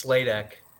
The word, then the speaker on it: Sladek: Is it Sladek? Sladek? – You're close. It's Sladek.